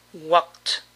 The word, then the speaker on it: walked: Usemos el verbo caminar, walk, walk, en el pasado, walked, walked. Para todo verbo que termine en sonido K, la ED suena T. Para dominar esta pronunciación es bueno decir walk y luego T por separado, así, walked, walked.